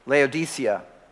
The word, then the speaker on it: Laodicea: That's why I think in Paul or when John uh, speaks of his revelation from Jesus about the church of Laodicea, and yes, that's how you pronounce it, that in fact, he says there, you're rich, wealthy, and need nothing.